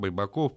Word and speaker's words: Байбаков: Он говорит, я когда к нему пришел, он говорит, меня на грузинский манер, он и Бери называли, э, ударение делали, байбаков.